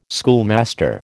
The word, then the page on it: schoolmaster: American English